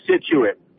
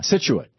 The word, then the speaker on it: Scituate: Yes, Scituate. – Yeah, Scituate.